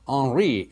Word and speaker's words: Henri: And there were people who in France followed a man named Henri, H-E-N-R-I, with a silent H, and they were called the Henricians because of the man that they followed who believed in the Passover and the weekly Sabbath.